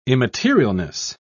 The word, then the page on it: immaterialness: British English